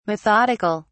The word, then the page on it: methodic(al): British English